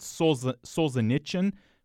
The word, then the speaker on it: Solzhenitsyn: I think she said Solzhenitsyn, which, if you don't exactly know how to say the Russian sounds, that ch on the last syllable is, you know, kind of reasonable.